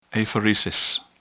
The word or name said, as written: apheresis